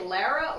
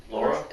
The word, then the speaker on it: Laura: And then is the designer, would you say Lara or Laura? – Laura. – It looks like Lara.